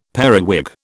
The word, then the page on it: periwig: British English